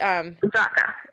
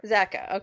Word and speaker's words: Zakka: Zakka. – Zakka, okay, that's what I thought, but I didn't want to sound like a real moron here.